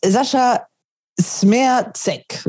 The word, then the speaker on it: Sascha Smerzek: Ähm, unser Hauptthema ist Sascha Smerzek. So wird er richtig ausgesprochen fast alle sprechen ihn falsch aus, aber ich habe ihn jetzt nochmal gefragt, wie es denn eigentlich richtig ist.